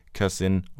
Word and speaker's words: cousin: Veldig mange nordmenn sliter med denne lyden, og bruker gjennomgående den ustemte S-en i ord som this, is, busy, cousin og music er alle ord som uttales med en stemt S, altså these, is, busy, cousin og music.